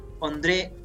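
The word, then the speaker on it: Ondřej: O sea, la J puede pronunciar como una I. André, André ah, Scubao.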